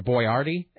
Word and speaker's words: Boyardee: Chef Boyardee, or Boyardee, as his name is actually pronounced, I guess he was actually like a three-star chef at one point.